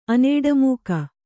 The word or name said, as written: ಅನೇಡಮೂಕ